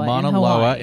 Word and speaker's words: Mauna Loa: It happened at the Mauna Loa. How do you say that? – Mauna Loa in Hawaii in 2013.